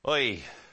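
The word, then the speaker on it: Oy (הוֹי): That word ah is actually in the Hebrew. But it's pronounced more like this. Oy.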